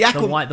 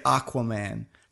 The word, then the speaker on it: Aquaman: Also, Americans say Aquaman, but we don't say it like that because we say Aqua. – It's one of those things. That yeah. – I used to say Aquaman.